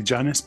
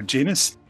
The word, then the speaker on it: Janus: We are chatting about the Janus conjunction, and we did have to check the pronunciation. Some would say Janus, but others prefer Janus.